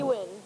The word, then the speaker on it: Wynn: Lee Wynn. – And how do you spell it? – W-Y-N-N.